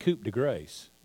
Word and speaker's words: coup de grace: This here is another French term, their coup de grace. Down south, we pronounce that coup de grace. That's what it's a little different.